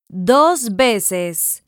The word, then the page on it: dos veces: Spanish